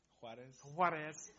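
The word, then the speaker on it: Juárez: Juarez.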